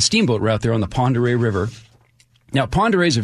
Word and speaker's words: Pend Oreille: Now, Ponderay County, okay, by my estimation, it's one of the two most mispronounced county names in the Evergreen State, the other being Wakayakum.